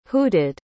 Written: hooted

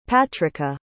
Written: patrica

